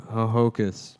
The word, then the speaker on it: Ho-Ho-Kus: Yeah, but it's spelled H-O-H-O-K-U-S. – That's really dumb. – Yeah, Ho-Ho-Coos.